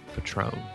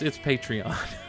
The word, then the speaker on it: Patreon: I believe it's pronounced Patron. – But no, it's Patreon. – Gaveltron. I think it's actually Patreon.